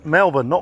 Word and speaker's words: Melbourne: How'd you pronounce it? – Melbourne. – Melbourne.